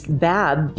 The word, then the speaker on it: Badb: Now, in Irish mythology, the war goddess Bab, B-A-D-B, Bab, features in the story of, now I'm not going to say this correctly, I know it, Tainbo Quanje.